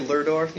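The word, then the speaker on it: Lerdorf: Laydorf, is that, am I pronouncing it correctly? – I say Lurdorf, but, yeah.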